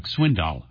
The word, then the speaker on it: Swindoll: He interviews such personalities as Dennis Quaid, Frank Turek, Chuck Swindoll, or as he says, Chuck Swindoll. I guess, you know, if Chuck Swindoll is saying Chuck Swindoll, that's how you pronounce his last name.